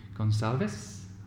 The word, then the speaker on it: Gonsalves: Okay, well, today I'm very happy to have with me Christine Gonsalves.